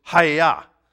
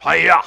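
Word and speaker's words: Hayah (הָיָה): In fact, in the Hebrew, the word I am there, the word is Hayah. The pronunciation is like deep down in your throat. It's like a karate chop. Hayah.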